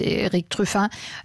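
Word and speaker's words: Erik Truffaz: Das ist Eric Truffat aus Frankreich, ein Jazz-Trompeter, beziehungsweise aus der Schweiz kommt er, aber spricht sich im Französisch aus, Eric Truffat.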